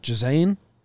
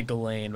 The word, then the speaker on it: Ghislaine: Jazane. – I say Ghislaine, but I think Ghislaine? There's technically a more like French.